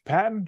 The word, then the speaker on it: Paton: Uh, I pronounce it George Paton, George Paton, George Paton. You know that is the general. – Not General George Patton. This is one T.